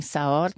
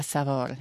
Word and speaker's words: saor: Allora c'ho le sarde in Savor, ci sono o in Saor, come dicono nel Veneto. Il mio papà, che dall'Istria, diceva Savor, dice, si deve pronunciare la V.